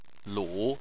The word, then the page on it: lu: 3